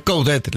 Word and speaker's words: Coudet: Coudet. – Coudet, se pronuncia la T. – Coudet. – Por eso, se pronuncia la T.